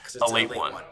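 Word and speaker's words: Ale-8-One: It's supposed to be pronounced a late one.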